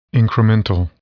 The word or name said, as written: incremental